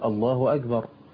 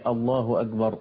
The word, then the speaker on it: الله أكبر: الله أكبر، لا يقول الألف هكذا وإنما ينطقها استفهامي، يقول الله أكبر، يمطط في الهمز يجعل هناك همز استفهامية قبل الألف، وإنما هي الله أكبر مباشرة هكذا، فيطيل همزا استفهامية فإذا بالمعنى يتحول إلى معنى كفري من غير قصد منه.